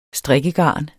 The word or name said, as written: strikkegarn